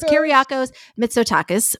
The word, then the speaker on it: Kyriakos Mitsotakis: So wrong. It's Kyriakos Mitsotakis, recently called climate change an opportunity to expand our tourism season.